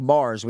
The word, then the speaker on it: Maher: Bill Maher. Such a weird last name, though. – Yeah, you say it like Mars without the S.